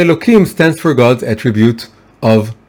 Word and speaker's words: Elohim: And the second name is, of course, Elohim, right? It's written with an H, but we pronounce it with a K.